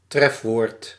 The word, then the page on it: entrée: /ɑ̃.tʁe/